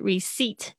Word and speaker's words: receipt: 就是补充的一个 deposit 提款，接下来是 commission 手续费，service charge 也是手续费，接下来是 application 申请单 apply 过来的 receipt，注意一下 p 不要发音。